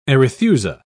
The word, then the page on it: arethusa: British English